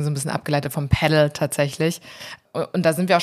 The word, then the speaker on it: Padel: Also irgendwie, man trifft ganz viele unterschiedliche Aussprachen und ich würde sagen, wir einigen uns auf Padel in dieser Folge, einfach weil es die allgemeingültige und internationale Aussprache ist. Auf Padel kommt man relativ schnell, wenn man sich den Ursprung dieser Sportart mal anschaut, denn die Wurzeln liegen in Mexiko. Mhm. Deswegen ist es diese etwas spanische Aussprache, aber weltweit hat sich Padel durchgesetzt.